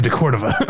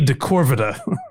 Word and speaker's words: de Cordova: Who there was a bit early on, or maybe it was just genuine, he didn't know how to pronounce, but he would always say Freddy de Corvida, like mispronouncing his name.